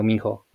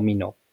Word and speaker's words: Minho: Bueno, puede ser, ahí estamos en un debate porque no sabemos si se pronuncia Minho o Mino, pero Dani dice que se puede pronunciar de cualquier manera. Yo digo que sí, porque él lo ha dicho de las dos maneras, entonces si él lo dijo de las dos maneras, de las dos maneras.